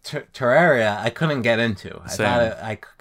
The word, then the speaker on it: Terraria: Is that how you pronounce it? – Yeah, Terraria.